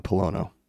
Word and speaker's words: Pollono: On my right, camera left, I have John Pollono.